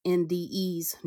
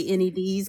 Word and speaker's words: NDEs: And like NDEs, I said it right this time, NDEs, near-death experiences, because I always say NEDs because my dyslexic ass.